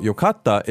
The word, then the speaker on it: yokatta: The intonation's a little bit different, right? Mm-hmm. One more time, just to practice. How do we say it was good? – Yokatta. Yokatta. – Yokatta. Mm-hmm. Sort of stating something. Mm-hmm. But then how do we say great? – Yokatta. Yokatta. So, express my feeling.